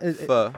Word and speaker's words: pho: Not, pho. P-H-O is how it's spelled. So you want to say pho, but it's not.